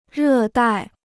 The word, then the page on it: 热带: rè dài